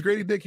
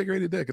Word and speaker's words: Grady Dick: His name is Grady Dick. You can say Grady Dick. It's fine.